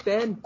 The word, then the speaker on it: Ben: My name is Ben. I spell it B-E-N.